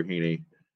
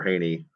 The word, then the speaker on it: Heaney: Andrew Heaney, or Haney, however you want to pronounce it, Sheriff Haney.